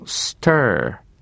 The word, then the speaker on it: stir: それはですね、今の stir ですね、舌を奥に向かって丸めることでこもった R のアメリカ式の発音ですね、この音が出ます。これしないとスターとなって、あの、星という意味になりますよね。